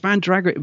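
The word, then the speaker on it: Mandragora: As it was written, the power of Man-Dragg-Ara will flood the earth. – Well. – Good trailer. – Good trailer, yeah. – Man-Dragg-Ara. – Man-Dragg-Ara. – Before you'll all be screaming, I know you will. It's Man-Dragg-Ara, Man-Dragg-Ara, it's Man-Dragg-Ara, sorry.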